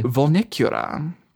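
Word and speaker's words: Vulnicura: Volnicura. Apparently, that's how you pronounce it, according to like six, you know, ego.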